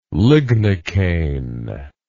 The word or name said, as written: lignocaine